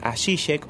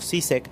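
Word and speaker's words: Žižek: Para los que no conocen a, Zizek o Zizek, también lo he escuchado pronunciar así,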